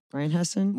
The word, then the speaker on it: Rheinhessen: So the Weiss is a blend of Riesling, Silvaner, and Moor Thurgau from the Rheinhessen. Rheinhessen? – Rheinhessen.